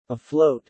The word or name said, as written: afloat